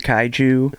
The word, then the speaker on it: Kaiju: Yeah, so my most recent project was VHS 2 Return of the Kaiju,